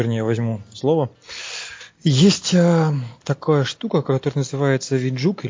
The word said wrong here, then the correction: vJUG: или virtual juke, ну, вернее, виджак, если уж говорить правильно и аккуратно, да, то есть виртуальная Java User Group.